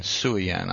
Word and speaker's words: Suayan: Miko Suyan, S-U-A-Y-A-N, Suyan, I believe is how it's pronounced.